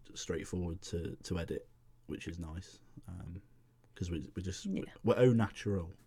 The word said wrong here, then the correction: au naturel: No, au naturel, is that the word?